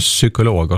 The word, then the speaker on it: psykolog: Men sen kanske försöka ta bort de här bokstäverna som inte uttalas alls i ljud, eller i ord, som exempelvis ljud. – Just det. – Jul. – och psykolog och sånt här.